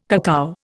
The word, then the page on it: cacao: Indian